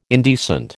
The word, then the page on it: indecent: American English